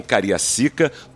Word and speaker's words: Cariacica: É isso mesmo. Não acho que eu estou falando errado, não. Foi em Cariacica, no Espírito Santo.